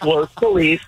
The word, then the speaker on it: Los Feliz: No, it's Los Feliz.